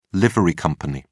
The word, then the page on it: livery company: British English